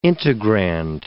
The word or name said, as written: integrand